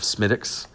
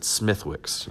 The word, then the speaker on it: Smithwick's: He also made fun of me for my pronunciation of Smittix. I drank that a long time ago and had just forgot that it had a particular pronunciation, because based off the way it's spelled, here in the U.S. we would pronounce it Smithwick's, you know, and that's not a beer that's drank a whole bunch down here in the South, especially.